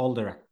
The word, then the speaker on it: Alderac: Ja, Alderac säger vi. ja, All the Rock Entertainment Group, ja, ja.